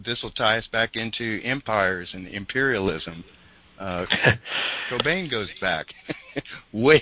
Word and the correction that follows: Kobani: Well, you know, interestingly enough, well, we need to mention, because it ties into the media too, but it's pronounced Kobane. This is a Turkish word, and it means company, like business company.